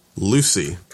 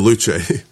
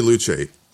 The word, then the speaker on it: Luce: We looked at the 2027 Ferrari Lucy. I'm saying that wrong on purpose because that'll make my girlfriend's head flip because she's Italian. It's the Luce. The Ferrari Luce.